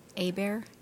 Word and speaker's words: Hebert: Stacy, S-T-A-C-I-E, Hebert, H-E-B-E-R-T.